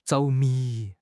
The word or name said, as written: 着迷